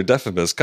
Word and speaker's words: Deiphobus: Trojan commander Diphobus. I forgot how it's pronounced now. You know, I'm So, yeah, who cares? – okay. Trojan commander Deiphobus comes in and vaguely accuses Xena of being a spy.